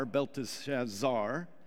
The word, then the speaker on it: Belteshazzar: It should be emphasized the last syllable there, Zar, Belteshazzar.